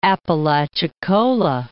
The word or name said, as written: Apalachicola